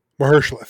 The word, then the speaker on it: Mahershala: Marshala, is that how you say his name? Marshala Ali, is that his name? – Mahershala.